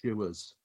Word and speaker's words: shoes: Let's take, for example, the word shoes. It seems as though there is just the one sound to that, shoes, it goes through. But actually in Black Country, it becomes shewers. So we actually pronounce more of the letters. We get a split vowel sound, if you like, within our words.